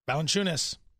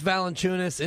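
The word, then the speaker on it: Valančiūnas: Jonas Valanciunas, and when you look at it and you start to go to the Eastern Block and work on your pronunciations, it's almost phonetically spelled. It's exactly how it should be pronounced when you look at it.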